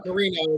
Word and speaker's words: Moreno: Moreno is usually how I say it.